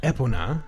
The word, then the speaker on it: Epona: But I know this should be Epona, because that's how they pronounce it in the Nintendo Epona. Of America videos.